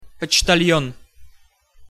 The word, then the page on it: poctal'on: Russian